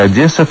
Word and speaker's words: Одесса: Что касается произношения города Одесса, то «э» оборотного в нем нет, тут ты прав на все 100%.